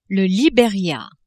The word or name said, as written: Liberia